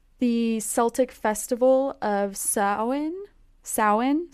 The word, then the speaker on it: Samhain: it's spelled so differently than how it's pronounced. It's S A M H A I N, but it's pronounced so in. So in.